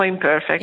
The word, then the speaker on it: Imperfect: Leggevo nel vostro sito, I am perfect o imperfect, come preferisci che si pronuncia? – Benissimo, imperfect.